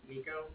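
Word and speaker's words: Niko: Would you like to say my name's Nico? That's N-I-K-O. And then I'm from the Bank of Cardiff, and that's spelled C-A-R-D-I-F-F as in Frank, right?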